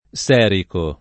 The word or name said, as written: serico